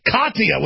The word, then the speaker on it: Katia: Tatia. I can't pronounce anything right. How do you say it? Katia with a K.